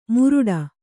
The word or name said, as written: ಮುರುಡ